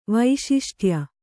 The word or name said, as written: ವೈಶಿಷ್ಟ್ಯ